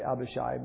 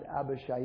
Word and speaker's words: Abishai: His name is actually Abishai, all right? So I may interchange that. I think a lot of times we hear the preachers say Abishai, but it's pronounced Abishai in the Hebrew.